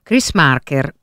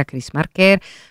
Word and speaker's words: Chris Marker: Lo possiamo pronunciare forse alla francese proprio perché francese era, Chris Marker, con proiezioni e masterclass, un appuntamento da non perdere.